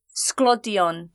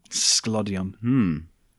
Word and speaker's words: sglodion: The Welsh word for chips is sglodion. – Sglodion. – Yes, but it's spelled like s-g-l-o, like, so it's spelled really weird.